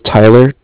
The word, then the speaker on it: Tyler: My name is Tyler, T-Y-L-E-R, and I'm calling from Cardiff. It's spelled C-A-R-D-I-F-F, as in Frank.